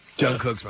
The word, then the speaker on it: Jungkook: Jungkook's my man. – Who's that? – One of the band members. Of Jungkook. – Jungkook? – Yeah, that's I believe how it's pronounced. It's just even spelled.